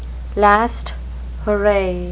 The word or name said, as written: last hurrah